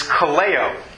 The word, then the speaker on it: kaleo: The way you pronounce that word is kaleo.